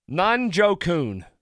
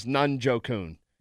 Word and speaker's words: Njoku: I wrote it down phonetically, so I remembered it. Nunjokun. David Nunjokun. Yep. So the Browns tight end. He's a wonderful player. – Yeah. – Who the Steelers had a whole lot of trouble with. It is three syllables, and it is over the air many times.